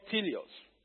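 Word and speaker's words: teleios: And when I look at the Greek meaning, it is spelled T-E-L-E-I-O-S. I call it teleos.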